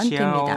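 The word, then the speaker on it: 지아오 (教): 가르치다인 동사의 의미일 때는 일성으로 발음을 하죠, 여러분. 지아오라고 발음해 주시면 지아오. 됩니다.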